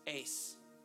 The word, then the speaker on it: eis: E-I-S. Ace.